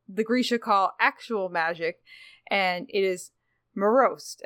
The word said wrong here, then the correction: merzost: Am I pronouncing that right, Jeff? – The word is merzost.